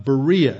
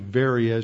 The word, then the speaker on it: Berea: They sent Paul and Silas away by night to Berea, as it's usually pronounced in English, or Berea, as it's pronounced in Greek.